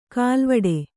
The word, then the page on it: ಖಲ್ವಾಟ: Kannada